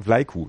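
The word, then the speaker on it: Vlaicu: Nein, Vlaiku. Das C wird wie ein K auch ausgesprochen.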